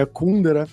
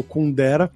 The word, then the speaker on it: Kundera: A pronúncia mais eh, tcheca seria Kundera, mas a gente geralmente conhece como Kundera, que é o autor do livro famosíssimo, A Insustentável Leveza do Ser.